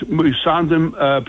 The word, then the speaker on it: Musandam: But his idea was very sound, is that the Musandam, I think it's pronounced Musandam Peninsula, belongs to Oman, and that's the southern border of the Strait of Hormuz, border of.